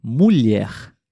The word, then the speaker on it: mulher: Mulher também tem a letra L e a letra H. E você pode tentar pronunciar substituindo a letra H pela letra I. Então você poderia tentar dizer mulher mulher. Um pouquinho mais rápido, mulher.